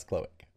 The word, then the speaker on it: cloaca: That's cloaca. – First I said a small Australian animal, and then I mispronounced it, and then got it.